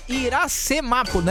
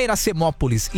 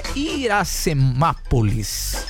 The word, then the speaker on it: Iracemápolis: Ó, o cara falando aqui, ó, é Iracemápolis, né? Não é Iracemópolis. Iracemápolis. Agora falei certo.